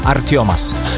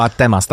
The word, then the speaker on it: Artemas: Artiomas. – Wow, naprawdę. – No, tak się wymawia, wiesz, a ty byś powiedział Artemas, tak? – Tak bym powiedział, no.